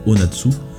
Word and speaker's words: 072: On va maintenant passer au Japon avec c'est une artiste qui se fait appeler donc en japonais Onatsu, mais ça s'écrit en fait en chiffres, c'est 072, mais ça se prononce Onatsu,